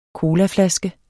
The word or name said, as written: colaflaske